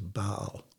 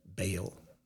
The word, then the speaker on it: Baal: Jezebel's god was Baal, or Baal, depending on how you pronounce it.